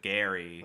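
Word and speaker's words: Gerry: So it's G-E-R-R-Y. That's still the same spelling as last week. But now we know that you don't pronounce it Gary. – Right. – It's Gary. – It's Gary. Do you guys hear the difference? So, okay, the first week I thought it was Jerry.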